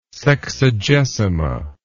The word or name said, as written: sexagesima